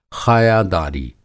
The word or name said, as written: ख़ाया दारी